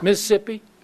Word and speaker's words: Mississippi: You notice how I pronounced it like Trent does? Mississippi.